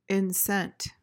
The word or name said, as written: incent